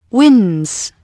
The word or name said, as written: winds